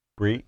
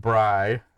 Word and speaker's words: Brye: It says, my MMA News conducted an interview with a pro WMMA fighter named, how would you pronounce B-R-Y-E? – Bree. – Bree? Okay. I was going to go with Bri, but that would be kind of weird.